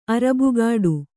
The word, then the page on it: ಅರಬುಗಾಡು: arabugāḍu